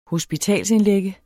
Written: hospitalsindlægge

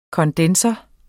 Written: kondensor

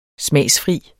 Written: smagsfri

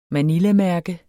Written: manilamærke

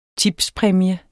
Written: tipspræmie

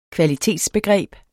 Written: kvalitetsbegreb

